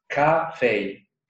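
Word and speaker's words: ka-fei (咖啡): For example, you say coffee, in pinyin it's ka-fei. K-A-F-E-I.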